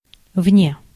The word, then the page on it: outside: American English; [ˌaʊt.ˈsaɪd]